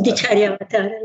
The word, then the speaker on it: Bodhicaryavatara: I wanted to ask you about one of the works that you've translated, the Bodhicaryavatara, I think I'm pronouncing that right. – Bodhicaryavatara, yes.